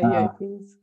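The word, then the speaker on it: UAP: Or is that, did I say that right? – Yeah, UAPs.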